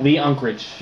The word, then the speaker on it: Lee Unkrich: It was Lee Unkrich? I never know how to pronounce his name. – Lee Unkrich.